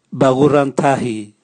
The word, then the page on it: bagurantahi: Raga